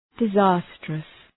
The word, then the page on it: disastrous: dı’zæstrəs